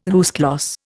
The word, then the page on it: à huis clos: Indian